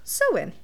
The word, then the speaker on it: Samhain: It looks like Samhain, but it's pronounced Samhain, where people would dress in costumes and light bonfires to chase off ghosts.